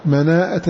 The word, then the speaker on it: مناءة: قال رحمه الله مناءة للمكي تلفظ بها هو، جاء بهمزة بعد الألف، مناءة للمكي زيد الهمزة.